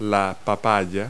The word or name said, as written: papaya